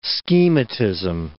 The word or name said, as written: schematism